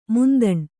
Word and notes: ಮಂತಣ: mantaṇa

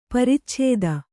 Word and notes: ಪರಿಚ್ಛೇದ: paricchēda

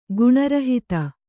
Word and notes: ಗುಣರಹಿತ: guṇa rahita

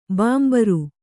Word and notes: ಬಾಂಬರು: bāmbaru

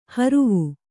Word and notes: ಹರುವೆ: haruve

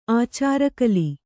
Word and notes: ಆಚಾರಕಲಿ: ācārakali

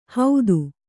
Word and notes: ಹೌದು: haudi

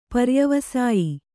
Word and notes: ಪರ್ಯವಸಾಯಿ: Kannada

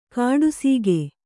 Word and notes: ಕಾಡುಸೀಗೆ: kāḍu sīge

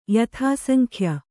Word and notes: ಯಥಾಸಂಖ್ಯ: yathāsankhya

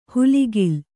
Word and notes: ಹುಲಿಗಿಲ್: huligil; kannada

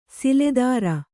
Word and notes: ಸಿಲೆದಾರ: siledāra

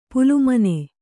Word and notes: ಪಾಲಮನಿ: pālamani; Kannada

